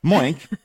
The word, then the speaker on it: Moink: That's Moo plus Oink. – And how do you pronounce that? Exactly. – All right. Moink is a meat subscription company on a mission to save the family farm.